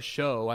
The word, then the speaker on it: Chão: It was a prefix meal, but essentially at Fogo de Chão is what, or show, I think they say, what Louise was telling me at work today. That's the way you should pronounce it.